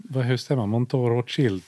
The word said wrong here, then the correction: Mouton: Jag vet inte hur man uttalar det. – Inte Monton, utan Mouton. – Mouton Rothschild.